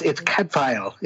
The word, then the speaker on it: Cadfael: So, brother, am I saying this right, Cadfael? – Yeah, it's Cadfael. It's Cadfael, Welsh, okay.